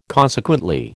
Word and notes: consequently: American English

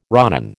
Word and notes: ronin: American English

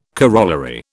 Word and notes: corollary: British English

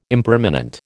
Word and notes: impermanent: American English

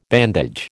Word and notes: bandage: American English

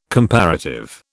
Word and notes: comparative: British English

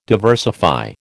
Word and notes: diversify: American English

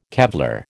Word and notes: Kevlar: American English

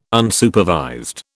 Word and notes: Unsupervised: British English

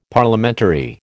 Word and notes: Parliamentary: American English

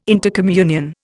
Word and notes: intercommunion: Indian

